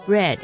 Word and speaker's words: bread: 面包叫做 bread， bread is spelled B R E A D。 – 嗯，好，请注意它的发音哈，它的 E A 不是发 E 哦，是 bread。